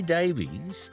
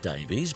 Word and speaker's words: Davies: How about The Kinks with Waterloo Sunset from 67 and Ray Davies, doesn't pronounce it Davies, but Davies. Don't know why, but that's Ray's style.